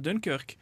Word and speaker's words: Dunkerque: Han alltid vet, Og han har ordentlig stålkontroll på alle ting, og det er derfor jeg valgte Dunkerque. – Uh, for det, det er sånn det uttales.